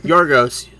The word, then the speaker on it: Yorgos: Yorgos.